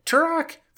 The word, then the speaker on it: Tourach: Tourach, first of all, assuming we're pronouncing that correctly, which I believe we are.